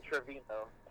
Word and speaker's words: Trevino: It's not G-L-E? – No, it's just A-N-G-E-L, Angel. – Okay. – And then Trevino. T-R-E-V-I-N-O. – Now, if I happen to put A-N-G-L-E, like angle, is that going to, they still going to know, they're going to recognize you, right? – They should, yeah, I hope so.